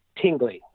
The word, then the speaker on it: Tingley: Tingley. T-I-N-G-L-E-Y.